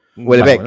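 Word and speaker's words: Houellebecq: Algunas compartidas, como en el caso del singular Hulebeck. ¿Se pronuncia así? – Sí, Hulebeck.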